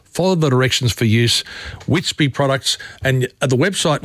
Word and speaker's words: Witsby: And look, looking after your family's health, it's got easier with the Witsby range. And I've told you about this. It's WITSBB. That's how it's spelt. It's Witsby.